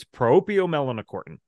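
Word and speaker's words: proopiomelanocortin: So here's a 9-syllable word for you. It's propiomelanocortin.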